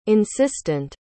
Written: insistant

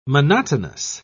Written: monotonous

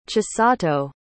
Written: chisato

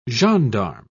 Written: gendarme

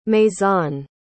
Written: maison

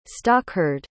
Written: stockhard

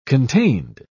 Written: contained

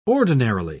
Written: ordinarily

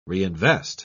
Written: reinvest

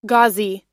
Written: ghazi

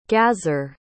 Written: gazar

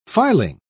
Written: filing

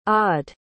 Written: aude

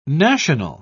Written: national

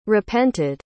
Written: repented

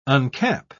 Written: uncap

